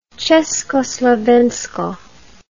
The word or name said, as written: Ceskoslovensko